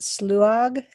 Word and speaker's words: sluagh: Or like what is, I don't even know how you would pronounce it, sluag, S-L-U-A-G-H? – Sluag is one way to pronounce it.